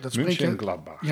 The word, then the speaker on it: Mönchengladbach: En dat heet nu Mönchengladbach. – Mönchengladbach.